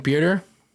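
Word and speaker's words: Pitre: Uh, safety Jalen Peter, um, that's how you pronounce his name.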